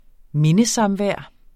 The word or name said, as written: mindesamvær